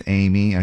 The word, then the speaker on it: Aimee: My name is Amy. I spell it A-I-M-E-E.